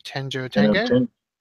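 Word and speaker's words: Tenjho Tenge: You haven't mentioned Tenno-Tenge yet. – You mean Tenjo Tenge? – Tenjo Tenge, however you pronounce it.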